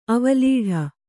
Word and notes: ಅವಲೀಢ: kannada

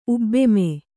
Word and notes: ಉಬ್ಬೆಮೆ: Kannada

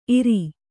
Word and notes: ಈರಿ: Kannada

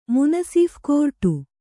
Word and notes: ಮುನಸೀಫ: munasīpha

